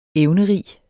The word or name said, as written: evnerig